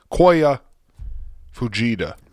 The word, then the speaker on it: Koya Fujita: Coyota Fuchida. Fuchida. Coyo. Koya Fujita. Fujita.